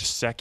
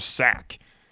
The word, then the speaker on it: Sek: Doc always pronounced his name Sack. He called him Sack Henry. He couldn't say Sek Henry, and it was like, I know you can say the word Sek. I don't know why you insist on calling him Sack, but he would not call him Sek.